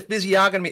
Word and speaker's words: physiognomy: He's got the physiognomy. Is that how you pronounce that? Physiognomy? – Yeah.